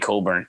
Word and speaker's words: Cockburn: Yeah, because it's spelled Cockburn. I know. – I forgot about that. When I heard the announcer say it, I was like, do they just have like a deal with ESPN that say his real name or something? Because there's no fucking way. There's no way, no pronunciation, no culture, no ever that is pronounced Coburn. – C-O-C-K-B-U-R-N. Yeah. Yeah. – R-O-C-K.